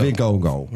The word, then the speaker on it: Vikingo: El Viking de El Hijo Vikingo. – Vikingo.